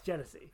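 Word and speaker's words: Genesse: But that's how you say it. – It's Genesee? – Yeah, it's Genesee.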